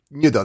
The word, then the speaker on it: Nyudo: Nyudo, that's how you pronounce it.